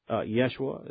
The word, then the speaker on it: Yeshua: Jesus in English, Yeshua is how it's pronounced in Hebrew, and I suspect Jesus may be how it's pronounced in Spanish.